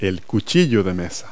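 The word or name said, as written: dinner knife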